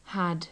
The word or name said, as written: had